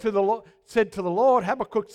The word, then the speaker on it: Habakkuk: Well, one of the famous prophets, Habakkuk, that's how they pronounce it in Israel, he said to the Lord, "How long, O Lord, must I call for help?"